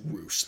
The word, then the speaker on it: Rush: It's spelled Rush, but it's pronounced Roosh.